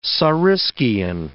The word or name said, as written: saurischian